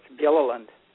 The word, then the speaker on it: Gilliland: I just keep it simple, but it's Gilliland. – Gilliland, hard G, Julie.